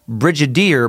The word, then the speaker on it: brigadier: This is now the second podcast I listen to where you've mispronounced the word brigadier, as in Brigadier General. You pronounce it with a hard G, as you're saying brigadier, but it's with a soft G like brigadier, Brigadier General.